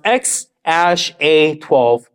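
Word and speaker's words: X Æ A-12: It's actually pronounced X Ash A 12 Musk.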